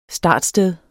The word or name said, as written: startsted